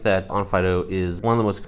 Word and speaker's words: Onfido: It's on Fido, and the way it's written in some countries, especially like European ones, they pronounce it on Fido, but on Fido is the name.